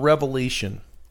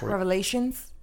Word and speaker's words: Revelation: But think about Revelation 18:23. – Not Revelations? – Revelation. – Hey, he said it right.